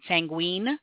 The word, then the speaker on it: sanguine: So I'm 61 years old, and you guys are a little more sanguine than I guess. I might not even be saying that right. Sanguine than I am. I still believe in this country. – That's correct. – Sanguine.